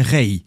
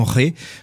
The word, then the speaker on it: Rey: La prononciation correcte de ce nom c'est bien Rey, avec un iode clairement prononcé à la fin, et non Ré, comme le voudrait un certain snobisme franco-français.